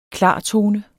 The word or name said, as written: klartone